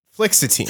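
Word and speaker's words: fluoxetine: Scientist Michael Bertram dosed male mosquito fish in an aquarium with high and low quantities of floxetine, the active ingredient. – Yeah. – Floxetine. Thank you. I knew I said it wrong.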